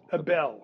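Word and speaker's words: Ebell: Abel. Okay. Abel. Accent on the second syllable, huh?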